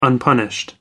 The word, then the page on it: unpunished: American English